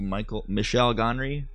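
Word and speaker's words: Michel Gondry: It was my first introduction to Michelle Gondry.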